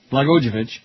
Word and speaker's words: Blagojevich: A bill signed into law Monday by Governor Rod Blagojevich, how do you say his name? Blagojevich, made Illinois the third state after Maryland and New Jersey ready to bypass the Electoral College in November.